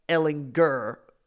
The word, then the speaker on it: Ehlinger: And Tom Herman went out of his way to say, first of all, it's Sam Ehlinger with a hard G.